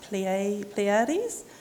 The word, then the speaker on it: Pleiades: In Greek language, now my Tereo is a lot better than my Greek, but it's pronounced Pleiades.